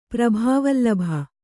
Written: ಪ್ರಭಾವಲ್ಲಭ

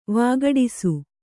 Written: ವಿಘಟಿಸು

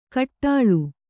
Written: ಘೋಟಾಳಿ

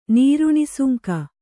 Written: ನೀರುಣಿಸುಂಕ

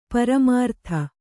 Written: ಪರಿಮೃತ

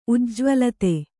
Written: ಉಜ್ಜ್ವಲಿತ

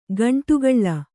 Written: ಗಂಡುಗೊಳ್ಳು

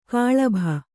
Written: ಕಲುಬಿ

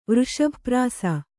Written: ವೃಷಭಪ್ರಾಸ

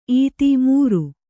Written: ಈತಿ

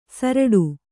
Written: ಸುರುಟಿ

